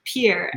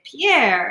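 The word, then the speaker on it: Pierre: So my grandparents, after they sold the farm outside of Oneida, they lived in Pier. Yep. And for the longest time, everyone would say Pier. And my grandparents were like, no, it's Pier.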